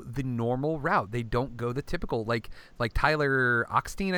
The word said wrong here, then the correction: Hoechlin: I think. I don't even know how to pronounce his name correctly. – Tyler Hoechlin. – Hoechlin.